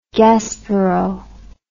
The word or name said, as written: gaspereau